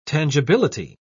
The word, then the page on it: tangibility: British English